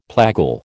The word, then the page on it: plagal: American English